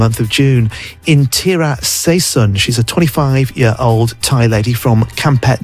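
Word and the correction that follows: Kamphaeng Phet: province, if I pronounce that right, Kampeng Pet province, although she's actually from Tak.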